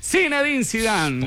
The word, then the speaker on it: Zinedine Zidane: Yo como Zidane, Sinani, ¿qué? ¿Cómo? Zinedine Zidane.